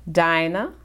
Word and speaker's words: Diana: D-I-A-N-A. – Okay, then. – Everybody, that... Diana is the European way of pronouncing Dinah. So when I went to Ghana, and we'll get into that some more, okay. – I saw someone, the nurse, with the same, spelled the same way. I said, how do you pronounce your name? She said, Dinah. So I said, well, my mother was correct.